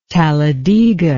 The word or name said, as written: talladega